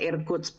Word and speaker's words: Irkutsk: Sa Irkutsk. Ano, tama po ba yung pronunciation natin? – Sa Irkutsk po mismo. Irkutsk.